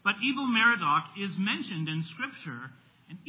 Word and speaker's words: Evil-Merodach: After Nebuchadnezzar died, he was first replaced by one of his sons named, it's pronounced Evil-Merodach. His name was not Merodach and he was really evil, okay? If we were to really say it, it would be Evil or Ewil.